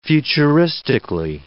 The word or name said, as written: futuristically